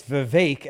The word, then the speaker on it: Vivek: NetJez, $5, says, Viva, it's Vivek, as in cake, Vegas attendee, not just as in Jessica.